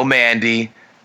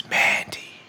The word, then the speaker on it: Mandy: No Mandy. No, not then. I want to see what they can do when given like 10, 15 minutes. – You said her name wrong. It's Mandy.